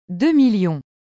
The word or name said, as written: 2000000